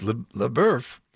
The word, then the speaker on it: LaBeouf: LaBeouf, beef as in beef in French. Remy the Beef. I don't know, but it is LaBeouf. That's how they're pronouncing their last names.